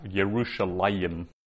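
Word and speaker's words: Yerushalayim (ירושלים): And we're to understand that, and basically pronounce it as if there's a missing letter there, which is a yod. So we actually pronounce it like that, Yerushalayim, even though the yod isn't there.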